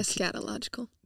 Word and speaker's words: eschatological: I'm going to come die so that my people may come together and be unified in this, I always butcher this word, eschatological. – Like, eschatological.